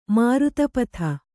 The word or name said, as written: ಮಾರುತಪಥ